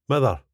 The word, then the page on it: mither: スコットランド英語